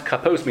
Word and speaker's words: kapos: And therefore, the blend of the singular and the plural, written kapas, pronounced kapos, means it's one entity but has many parts to it.